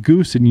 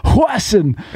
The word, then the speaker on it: Goosen: And David Webberly said one day, not at the going away party, he said that golfer, Retief Goosen, you know, y'all are saying it wrong. It's Retief Hwasson.